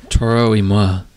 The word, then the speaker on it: Toro y Moi: Oh, Toro y Moi. It's French. – Yeah, I don't know how to pronounce it, so there you go. – Part of it is French, at least.